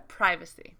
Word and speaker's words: privacy: The stress on privacy is actually the same. American and British English both put it on that first syllable. – Yeah. – But the pri and the pri are different.